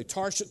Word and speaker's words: Tarshish: I had to work hard to pronounce that correctly. Tarshish, because I kept thinking of Saul of Tarsus, okay? So it kept messing me up.